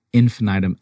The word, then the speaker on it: Infinitum: Yeah, it's like infinity. It's Latin. Infinitum, I-N-F-I-N-I-T-U-M.